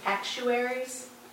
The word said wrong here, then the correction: actuator: am I saying that right? – Actuator. – I always say actuary.